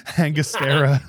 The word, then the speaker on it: Angostura: How do you say the bitters are? – Angostura? – Yeah, that's it. Angostura.